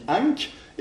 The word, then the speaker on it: ankh: Le deuxième, la croix qu'on dit ankh, eh bien, ça se lit ankh en égyptien et ça veut dire vie.